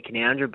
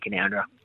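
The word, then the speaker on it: Canowindra: It's not always pronounced as it's spelt, but, yeah, obviously we're quite proud. Out in the central west of New South Wales, it's definitely Canoundra,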